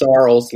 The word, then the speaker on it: Sorrells: My name is Sorrels like Charles.